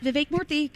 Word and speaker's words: Vivek Murthy: Now, coming up in a little over an hour, about an hour, Surgeon General Dr. Vivek Murthy will be on with us. Am I saying his name correctly? – You got it. Vivek Murthy. Good job.